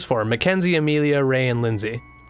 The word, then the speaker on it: Linsay: How do you fucking pronounce her name? – Lindsay. – It is just Lindsay? Okay. Mm-hmm. But there's no D in it. It's just L-I-N-S-A-Y.